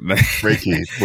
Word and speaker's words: Reiki: Reiki. – R-A-Y-K-E-Y. – That's a perfect way to pronunciation. Perfect. – Reiki.